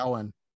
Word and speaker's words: Samhain: Well, the original, was it Samhain? How did you say it? – Samhain? Yeah, Samhain.